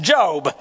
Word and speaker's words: Job: which is actually pronounced Job.